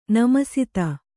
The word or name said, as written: ನಮಸ್ತೇ